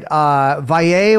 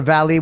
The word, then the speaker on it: Valle: Valle or Valley, which is the proper pronunciation? – I mean, Valle is the right way, but the U.S. Valley is fine.